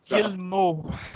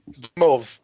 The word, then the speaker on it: Guillemot: It's pronounced Gilmo. – Yeah. – Oh, this guy. Oh, God. – Gimov. I'll call him Gimov. – No, Gilmo.